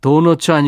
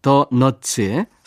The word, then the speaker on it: 더 너츠: 도너츠 아니고 발음을 잘해야죠. 더 너츠의 사랑의 바보까지 이어서 전해드리겠습니다.